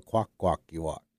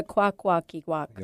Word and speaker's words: Kwakwaka’wakw: Well, you always got to say it like this. Do the walk of the Kwakwaka’wakw. Say the whole thing. – Do the walk of the Kwakwaka’wakw.